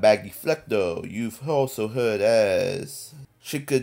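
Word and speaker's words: Flecknoe: I believe that's how you pronounce her name. – Yeah, Flecno.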